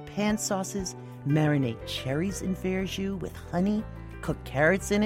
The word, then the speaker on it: verjus: Have you tried Verju yet? That's spelled V-E-R-J-U-S.